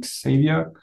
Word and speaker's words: Xavier: Ich würde sagen, Javier wird es ausgesprochen, ne? Wie Xavier, Javier.